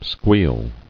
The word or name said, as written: Squeal